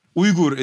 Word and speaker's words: Uyghur: Uyghur is how you're supposed to say it.